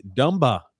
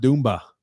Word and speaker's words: Dumba: Scott L saying it's Dumba, not Doomba.